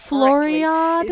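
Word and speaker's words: Floriade: very famous horticultural event called the Floridada. I'm not pronouncing that Floriad. – Correctly. Floriad? Yeah, thank you.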